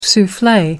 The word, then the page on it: souffle: American English